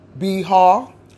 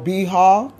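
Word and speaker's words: B'har: Hello, I thank God for the Torah portion number 32, which is called Biha, and is spelled B apostrophe H A R, and it's spelled Biha, and it means on the mount.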